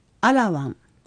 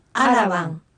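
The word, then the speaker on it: アㇻワン: 次に 7 という意味のアラワン、アラワン。 – アラワンのラは小さなラなので、発音する際にアラワンとラを大きく発音しないように注意しましょう。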